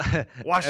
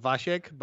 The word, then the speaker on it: Wasiek: No, Wasiek. As many people, yeah, Wasiek. It's in Polish, it's actually pronounced Wasiek, but Wasiek. Yeah, that's Wasiek.